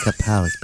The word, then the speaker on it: Capote: On this date, going way back to 1924, Truman Capote. – Oh. – But it looks like Capote. – Yeah. – Well, it's spelled Capote, but it's Capote. – Oh, you mean like compote?